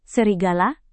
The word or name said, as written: serigala